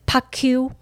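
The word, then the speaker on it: PaQ: 然后呢，为了让外国人好发音，所以他叫头呃，他把它取名叫做怕 Q。没错，就是怕亏的谐音啊，哈，或者是叫音译好了，叫做怕 Q。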